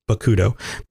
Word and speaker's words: Bakuto: I think that's the way you pronounce it. Bakudo. B-A-K-U-T-O. That means gambler.